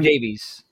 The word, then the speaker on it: Davies: Are we saying Davies or Davis? Because I'm Davies. – Jeremy Davies. Okay. Well, I'm so used to Ben Davis on because I do the Welsh pronunciation. I'm just used to doing the Welsh pronunciation of it, which is just Davis.